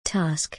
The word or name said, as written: Task